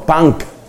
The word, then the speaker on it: punk: Se escribe P-U-N-K. Y la pronunciación es algo así como punk. Punk. Uno lo leería en español punk, pero por supuesto en inglés lo dicen punk.